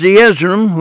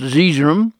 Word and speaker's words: Zeezrom: As Zeezrom, which is sometimes pronounced Zeezrom,